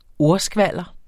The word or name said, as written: ordskvalder